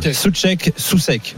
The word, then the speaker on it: Souček: Souček, Souček, voilà. – On va faire alors, Souček, ouais, on prononce pas le U.